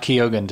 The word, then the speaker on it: Keoghan: I caught Keoghan too.